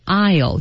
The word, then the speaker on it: aisle: 他就沿着一路沿着这个 aisle 这个过道 aisle 注意啊、yeah. A I S L E 读音 aisle Yeah, an aisle is a long narrow space between the rows of shelves in a shop.